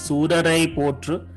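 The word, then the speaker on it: Soorarai Pottru: tamil word but i think it is surarai potru which means uh, praise the brave people Surar, suraveer you say no like that surarai yeah, potru right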